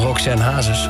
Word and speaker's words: Roxanne Hazes: Ik denk, ja, het is gewoon Roxanne Hazes.